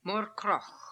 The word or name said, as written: Morkrokh